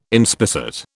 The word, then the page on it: inspissate: British English